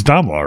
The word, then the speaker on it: Damla: How else would you pronounce food? D-A-M-L-A. It's Damla, right? – Damla, yeah.